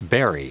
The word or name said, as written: bury